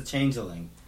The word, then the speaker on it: Changeling: No, it's The Changeling.